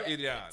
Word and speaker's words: Iran: It's Iran. You have to roll your R. Iran.